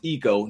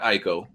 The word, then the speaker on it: ICO: It's pronounced Eco, not Ico. – I'll take that. – Can it go either way, though? – No, it's Eco. That's the way Japanese pronounce their I's.